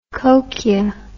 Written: kochia

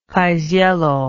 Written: paisiello

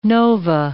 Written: nova

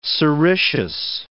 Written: sericeous

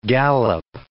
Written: galop

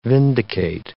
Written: vindicate

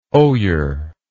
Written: oyer

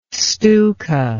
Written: Stuka

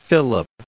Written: Philip

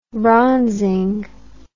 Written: bronzing

